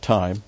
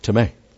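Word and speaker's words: time (τιμή): T-I-M, long E. Now, this is not time, but time.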